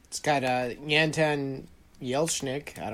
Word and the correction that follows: Anton Yelchin: Anton Yelchin? – Yeah, that guy.